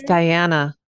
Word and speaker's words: Diana: Swillinger? No, it's Diana.